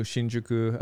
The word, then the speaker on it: Hsinchuku: Can you tell us a little bit about this new Hsinchuku location? – I like that you're saying it correctly.